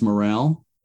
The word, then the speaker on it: Morrell: It's pronounced Morrell. – Yes. – Yes. – Oh, okay. That's tricky.